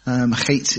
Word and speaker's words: Heth: You'll find it typically spelled C, it's kind of a silent C, but H-E-T-H, chet is the pronunciation or something along those lines.